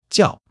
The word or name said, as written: 叫